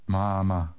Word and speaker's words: máma: Listen to the difference between the short and the long A in Czech. – Mama. Mama. – Now read number 10. – Máma.